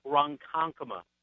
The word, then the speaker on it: Ronkonkoma: Lake Ronkonkoma, New York.